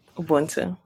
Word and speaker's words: Ubuntu: Ubuntu. Is that how you pronounce it? – Yes, that's how you say Ubuntu.